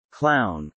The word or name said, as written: clown